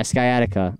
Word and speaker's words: sciatica: Sciatica? – Yeah, sciatica.